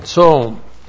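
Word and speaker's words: tzom (צום): And then we have the noun tzom, which looks like this in the Hebrew, T-S long O-M, tzom.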